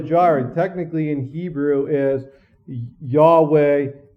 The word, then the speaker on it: Yahweh: In the Old Testament, the personal name of God, does anybody know what the Hebrew pronunciation is? Yahweh. The German pronunciation is what? Jehovah. And the reason why that's the Germans pronounced it, because it in the Hebrew, it's actually Yah, like a hard breathing sound. And the Germans couldn't pronunciate that, so it was a Jah sound.